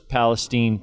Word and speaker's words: Palestine: For the most part, I'm witnessing people, it doesn't matter where it's from, whether it's Topeka, Kansas, or it's Palestine, Texas. Did I say that right? – You said that perfect.